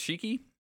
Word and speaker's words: Shiki: Your note says, as Kingdom Hearts lore experts, no, it's is it pronounced Shiki? – Yep. – I always second guess myself. Shiki appears on screen and says, "You forge a pact with me."